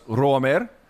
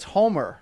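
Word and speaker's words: Roamer: I think in Brazil it's Homer, right? – He's Colombian, not Brazilian. So it's, you got to roll the R there at the front.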